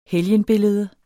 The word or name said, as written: helgenbillede